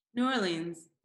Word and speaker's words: New Orleans: Yeah, well, most people don't pronounce the whole New Orleans. It's like a little bit of, it's drawn out a little bit more, but it's totally fine. New Orleans. It kind of slurs, doesn't it?